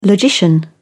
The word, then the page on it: logician: British English